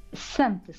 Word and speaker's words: SAMP: Como é que se pronuncia? SAMP, não é? – SAMP. Sociedade Artística Musical dos Poços.